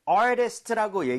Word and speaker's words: artist: 엄밀히 따지면 그게 더 깔끔한 발음이긴 해요. artist, right? 하지만 빠르게 읽게 되면 T가 flap 현상이라고 해서 리을 비슷하게 발음이 될수 있어요. So, 부드럽게 try만 해볼게요. artist보다 artist인 것처럼. 오케이, 네.